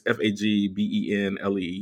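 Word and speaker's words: Fagbenle: Like, especially when you pair her with the 48-year-old OT Fagbenelli. I thought that's how we say his name. He's like, it's Fagbenelli.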